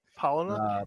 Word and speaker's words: Palahniuk: Palahniuk.